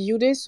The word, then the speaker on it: Eudes: Oui, justement, euh, euh alors je ne sais pas s'il faut qu'on dise Eudes parce que Zaka, il dit Eud, alors c'est à la française. Euh, comment tu veux qu'on prononce ton prénom Alors je, dis Youdes ou Eud.